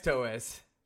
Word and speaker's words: Toews: Yeah, it's Toews. Taos, Tays, Tweens, Twos.